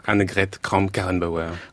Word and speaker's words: Annegret Kramp-Karrenbauer: Annegret Kramp-Karrenbauer.